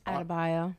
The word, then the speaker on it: Adebayo: Adebayo. – How you say it? – Adebayo. – That's my son's favorite player. – Do you know how to say his name now? – No. – Bam Adebayo? – Bam Adebayo.